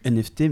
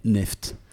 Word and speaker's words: NFT: On ne, dit plus, on ne dit plus NFT, mais neft. Enfin, c'est ce que le, le euh, magazine The Verge euh, propose. C'est ainsi qu'il faudrait prononcer ce token, qui est la mode.